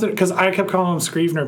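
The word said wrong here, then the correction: Streveler: but on the TV they kept calling him Strevner. – Strevler. – I was pronouncing it wrong the whole time.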